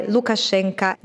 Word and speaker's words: Lukashenko: Perché quando la O, soprattutto in generale, non è accentata, si pronuncia A. E generalmente, quindi quando abbiamo la O alla fine dei cognomi, come Lukashenko, in realtà si dovrebbero pronunciare Lukashenko nella loro declinazione russa.